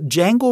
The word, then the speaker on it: Django: Und äh, Django mit dem Sa The D is Silent ist das Vorbild für George Lucas gewesen,